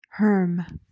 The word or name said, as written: herm